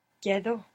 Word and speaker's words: quedo: Quedo. – The E doesn't split here, but good for trying. – Quedo.